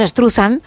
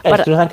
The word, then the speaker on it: Struzan: Struzan o Struzan o como cada uno lo quiera pronunciar. Nosotros decimos Struzan. – Struzan, que es más fácil.